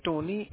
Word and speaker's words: Tony: My name is Tony. It's spelled like T-O-N-Y with the last name initial S as a Sam.